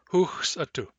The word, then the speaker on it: huxatu: Last, huxatu. Huxatu means resting or taking a break. The syllables are hux, a, tu.